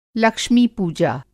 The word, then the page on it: लक्ष्मी-पूजा: Lakshmi Pooja